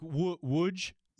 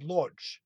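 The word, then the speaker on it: Łódź: The DZ is like a J or a DGE. Like, well, now you've given it to him. Now he can't. – Right. – So how do you pronounce it? – So now it's basically like Wudge.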